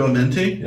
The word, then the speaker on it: Amenti: Make sure I'm pronouncing this right. Path to Amenti.